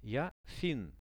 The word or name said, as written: ja finn